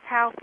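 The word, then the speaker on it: Halff: Am I saying it correctly? – Yes, Dr. Charles Health.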